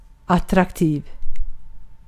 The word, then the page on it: attractive: [ə.ˈtɹæk.tɪv]